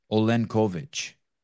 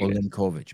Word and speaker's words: Olenkowicz: Olenkowicz. – Yes, like Olenkowicz.